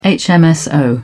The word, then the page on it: hmso: British English